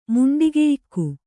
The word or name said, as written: ಮುಂಡಿಗೆಯಿಕ್ಕು